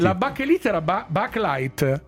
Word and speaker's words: Bakelite: Che è una di quelle cose che in realtà si pronunciava Bakelite. In italiano è diventata Bachelite, come Colgate che è diventato Colgate.